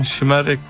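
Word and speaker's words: Şımarık: Shmarek.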